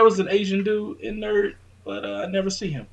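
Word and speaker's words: N.E.R.D: Nerd. – Yeah. We had Nerd. It's NERD, right? You got to say it like that. So. – No, you don't. You just say Nerd.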